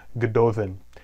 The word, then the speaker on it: Gododdin: the double D's there are pronounced as a TH sound, Gododdin.